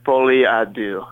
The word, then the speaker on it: Folie à Deux: It's Folie à Deux.